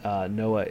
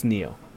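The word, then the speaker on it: Nioh: Noah is a Souls-like samurai ninja game, which is very cool. – I always pronounce it Noah. – Neo. You're right. It's Neo.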